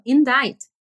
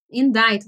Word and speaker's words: indict: E tudo bem a pronúncia, tranquilo, indict, indict. Você acha que é uma palavra tranquila? Até você lê ela. Indict se escreve I N D I C T não tem nada a ver a escrita com a pronúncia.